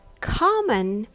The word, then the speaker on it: common: Common has the stress on the first syllable, con. And come on has the stress on the second syllable, on.